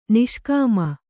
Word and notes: ನಿಷ್ಕಾಮ: niṣkāma

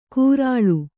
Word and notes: ಕೂರಾಳು: kūrāḷu

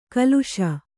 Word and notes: ಕಲುಷ: kaluṣa

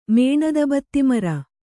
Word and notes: ಮೇಣದಬತ್ತಿಮರ: kannada

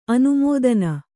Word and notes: ಅನುಮೋದನೆ: anumōdane

